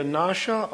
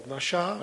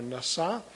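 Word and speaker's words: nasha (נָשָׂא): Actually, the accent should be on the last syllable, nasha or nasa.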